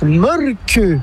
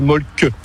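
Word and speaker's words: Mölkky: Inventé en Finlande en 1996, ce jeu de quilles s'écrit M-O-T-R-E-M-A-L-K-K-Y. – Ça s'écrit Molky, mais ça se prononce Molke. – Le Molky. – Plutôt un E qu'un E, mais pas un U. Donc ça fait Molke.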